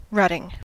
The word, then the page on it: rutting: American English